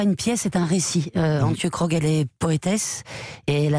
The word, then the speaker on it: Antje Krog: Antje Krog, une poétesse sud-africaine.